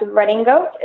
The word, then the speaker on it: redingote: And the name came about, it's pronounced Redding Goat. It's spelled R-E-D-I-N-G-O-T-E. And that is a French derivative of the English word riding coat.